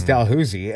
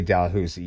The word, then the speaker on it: Dalhousie: And then down there in Halifax, when you say Dalhousie, you're immediately, they catch the Ontario on you right away, and you're outlawed forever, essentially. Mm. So yeah, no, I've clearly went to the standard Halifax pronunciation.